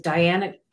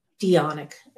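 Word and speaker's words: Dianic: In the Dionic or Dionic, depending on where you're from and how you pronounce it, that is a strictly women only tradition where they they focus on the goddess and not necessarily the god and the goddess.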